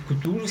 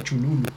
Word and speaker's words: Cthulhu: Cthulhu, sí, sí, pero tenía un amigo, Carlos, que decía Chululu.